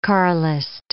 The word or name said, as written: Carlist